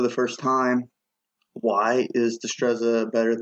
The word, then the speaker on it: Destreza: So I brought you on today to talk about Destreza. Am I saying that right? – Yeah.